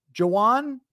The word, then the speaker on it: Jawan: Also confirmed that it is Jawan in the same way that you would say like Jawan Howard.